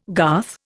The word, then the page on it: garth: Indian